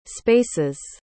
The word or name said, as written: spaces